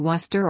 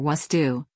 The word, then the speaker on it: Worcester: Worcester, pronounced Worcester or Wustu, is among a list of several, including Woburn, Tewkesbury, Gloucester, Leicester, Tingsboro, Lowell, Peabody, Norfolk, and Scituate.